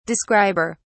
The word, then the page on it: describer: British English